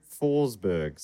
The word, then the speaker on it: Forsberg: Also, the first syllable in my last name is pronounced Fors, not Force. Drew Forsberg.